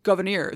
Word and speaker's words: Gouverneur: Again, his name is derived from his mother's Huguenot French Protestant name, surname, pronounced Gouverneur.